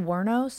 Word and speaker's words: Wuornos: How do I pronounce her last name? Eileen Wuornos? – Yeah. – Is that how you say it? – Mm-hmm.